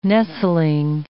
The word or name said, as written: nestling